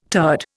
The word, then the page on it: turd: Indian